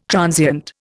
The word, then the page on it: transient: Indian